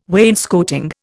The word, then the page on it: wainscoting: Indian